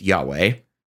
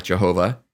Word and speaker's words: Yahweh: I mean, God famously revealed his divine name to Moses in the burning bush, what we abbreviate in Hebrew, YHWH, what would be probably pronounced Yahweh. Definitely not Jehovah.